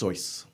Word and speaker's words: Seuss: It's pronounced Seuss. It's pronounced Seuss. Go call Seuss.